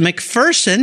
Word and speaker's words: McPherson: And I learned from Amanda this morning, I've been saying it wrong all these years and I feel terrible. It's a McPherson, not McPherson.